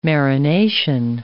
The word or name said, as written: marination